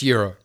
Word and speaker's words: gyro: Maybe they meant the gyro. – Which is the euro. – I'm talking about the food. – It pronounced euro. – Gyro. – Is pronounced euro.